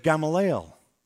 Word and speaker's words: Gamaliel: He studied at the feet of Gamaliel. I said it right that time. Turn to your neighbor and just say Gamaliel three times.